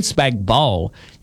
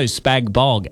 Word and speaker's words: spag bol: Is it spag bol or spag bog? – Spag bol, the G is silent.